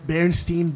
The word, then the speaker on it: Berenstain: And it's just little examples of that, like going back into time, like Berenstain Bears. It's Stein, like S-T-E-I-N. – And you hear everybody pronounce Berenstain.